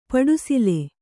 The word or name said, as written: ಪಡುಸಿಲೆ